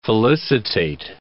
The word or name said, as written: felicitate